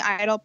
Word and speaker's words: Eitel: And for myself, I do have my Stephanie Eitle public figure page. Last name is spelled E-I-T-E-L.